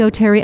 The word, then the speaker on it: O'Terry: And please follow me on social, Candy O'Terry Official on Facebook and Candy O'Terry on all other platforms. The last name is spelled O-T-E-R-R-Y.